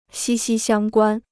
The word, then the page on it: 息息相关: xī xī xiāng guān